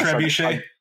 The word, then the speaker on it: trebuchet: It's what, the trebuchet? Am I saying that right? That's like the old medieval thing that flings that pumpkin. What do you got? – Yeah, trebuchet. – I believe. – Am I saying that right? – You're correct.